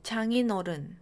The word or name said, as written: 장인 어른